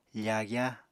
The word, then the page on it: fagya: Apatani